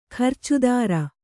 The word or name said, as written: ಖರ್ಚುದಾರ